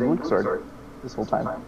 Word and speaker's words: Luxord: Yeah, screw you, it's Luxord.